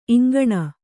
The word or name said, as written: ಇಂಗಣ